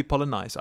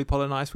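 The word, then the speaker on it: Polynice: Bimbo Coles, Vin the Baker Baker, Alden Polonice, maybe Polonice. I think it's probably Polonice. We've been saying it wrong all show.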